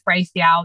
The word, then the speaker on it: Raphael: So all of my Instagram and all of my everything is just empowered with Raphael. You can just Google that. Sometimes if you just Google empower with and then so my name is spelled the same as the archangel, pronounced slightly differently.